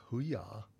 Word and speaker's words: Hujar: Is it Hujar? Right. H-U-J-A-R. – Yeah. – So that might be Hujar. – Yeah, Hujar.